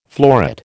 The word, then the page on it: floret: American English